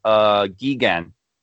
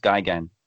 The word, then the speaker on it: Gigan: I think a lot of people would mispronounce it Gigan. But it's actually pronounced Gigan.